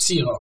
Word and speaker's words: Zierock: Seahawk, that's a complicated German name. – Z-I-E-R-O-C-K. – Ah, okay, Xerox. Okay, so you guys are all Xerox. – Yes. – Right, like Xerox, but almost.